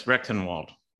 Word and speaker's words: Rectenwald: Did I say your name right? – Yes, Rectenwald.